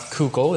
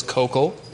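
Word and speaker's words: Koukl: I have been informed that that's how you pronounce his name. It is not Kuchel, it is Kochel.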